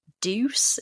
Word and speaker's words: Deuce: And he was also in a 90s pop group called Deuce, if that's how it's pronounced. – Oh, wow. – Like D-E-U-C-E.